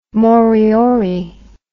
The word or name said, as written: moriori